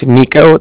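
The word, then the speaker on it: Niko: So like I said, my name's Nico. That's N-I-K-O. – Mm-hmm. – And then the Bank of Cardiff is spelled C-A-R, D as in dog, I, F-F as in Frank, Frank, so it's like car and the different.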